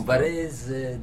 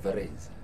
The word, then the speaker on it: Varese: Varese, non si sente che il tuo accento è diverso dal nostro. – Varese, devi chiudere molto lei. Varese. – Varese? – Sì.